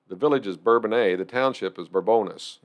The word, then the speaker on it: Bourbonnais: Yeah, so I moved, lived in Bourbonnais. I'm pronouncing that right. – So yes, you are.